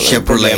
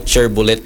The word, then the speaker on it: Chevrolet: Ang sakyanan ni mo nga mahal ka ayon, nindot ka ayon, wala Chevrolet. – Problema. Chevrolet. Itong nakailaw, chair bullet daw. Chair bullet ang iyong mag-pronounce.